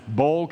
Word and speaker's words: Boyle: There's a Y in there, but you don't pronounce it. Boyle County.